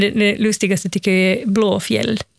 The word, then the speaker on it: Blåfield: Det som folk brukar tendera att uttala Blåfield, alltså blå, alltså en F-I-E-L-D. Ja. Alltså en gammal stavning av ordet fjäll. Så det namnet är Blåfjäll.